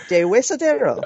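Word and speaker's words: Deshuesadero: De Huesadero.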